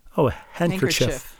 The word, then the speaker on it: handkerchief: But the handkerchief was always pronounced with a soft I. Oh, handkerchief.